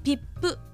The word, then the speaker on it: 比布: 漢字で書くと比較の日に布と書いてピップと読む